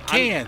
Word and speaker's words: Cannes: Yeah, Cannes. – I'm not sure. No S. The S is silent.